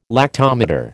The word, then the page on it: lactometer: American English